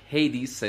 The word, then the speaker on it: Hades: Hades es la pronunciación en inglés. Ya saben cómo soy de pocho. Hades. Hades. Hades y no el jugo.